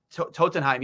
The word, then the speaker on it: Tottenham: Tottenham, yes. Sorry, I absolutely butchered that.